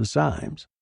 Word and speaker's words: Symes: At any rate, ridiculous neurotic delusion is the explanation favored by most of the friends of the Symes. One syllable, you know. Rhymes with limes.